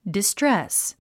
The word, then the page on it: distress: distrés; ディストレス